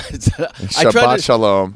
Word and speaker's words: Shabbat Shalom: Shalom Shalut. – That's not how you say it. – It's not. – Shabbat Shalom. And it is Shabbos, so yeah, you got it right.